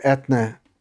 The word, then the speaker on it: Eithne: It's just how, well, I mean, that's how Etna, E-I-T-H-N-E, her given name, is pronounced.